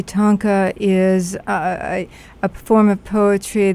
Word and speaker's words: Tonka: And you're president of the Tonka Society of America. Am I pronouncing that correctly? – You are.